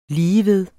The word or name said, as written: ligeved